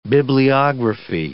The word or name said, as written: bibliography